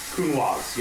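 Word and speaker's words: Counoise: This is a Cunoise. Did I pronounce it right? – Cunoise, yes.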